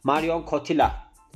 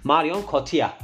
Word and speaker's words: Marion Cotillard: Yani İngilizcesine bakarsak Marion Cotilla diye de okunuyor. Ama biz Fransız olduğu için aktris tabii ki Fransızca telaffuzundan sorumluyuz.